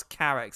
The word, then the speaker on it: Carax: That rhymes with, yes, Carax as in carrots.